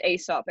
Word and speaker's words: Aesop: No, It is pronounced Aesop, everyone on Aesop. The chat. And I only learned that when I was in their office and everyone was like, welcome to Aesop.